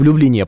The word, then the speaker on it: Люблине: Но, например, я нахожусь в Люблине, правильно говорить.